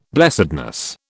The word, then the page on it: blessedness: British English